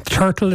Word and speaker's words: Tertill: Turtle is spelled T-E-R-T-I-L-L if you're looking it up online.